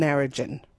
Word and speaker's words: Narrogin: Now, those G's, mate, they're the soft G's, so Wagon and Narrogin.